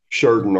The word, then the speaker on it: Sheridan: Well, you know, my, my parents are actually from, and I'm going to pronounce this correctly, Sheridan, Sheridan. That that's the correct way to pronounce it. I don't know if you know that, but Sheridan, Arkansas.